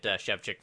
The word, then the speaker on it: Shevchik: It took me years to, well, not years, but a couple events to get Shevchik figured out. – you just put it into two English words, Shevchik. That's it.